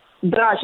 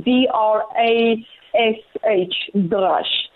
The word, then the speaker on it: Drash: That would be Dalit Reish Shin. Drash. If you want to transliterate it, it will be D-R-A-S-H, Drash.